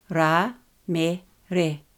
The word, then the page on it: Ramere: Ra–me–re